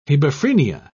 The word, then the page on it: hebephrenia: British English